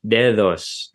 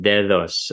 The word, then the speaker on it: dedos: For example, if you take Spanish, what would have been an underlying form dedos, the way it's pronounced in Spanish is dedos as a fricative, dedos, and I don't have the IPA character ready, so I'm just going to use a capital D.